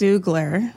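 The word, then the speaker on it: Xoogler: What's the proper pronunciation of ex-Oogler? Is it ex-Oogler or Zoogler? – It's Zoogler.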